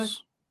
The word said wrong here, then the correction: Vernon Hargreaves: Vernon Hargreaves.